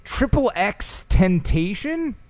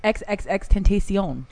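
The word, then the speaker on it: XXXTentacion: Now, how do you say this name? Triple X Tentation? – Yeah, it's XXX Tentacion.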